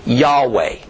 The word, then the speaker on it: Yahweh: Do we vocalize it like Yehoiada and Yehoshua, Joshua, and thus say the Lord's name is Yehovah, or do we vocalize it like in Yeshua or Yah and say Yehweh or Yahweh?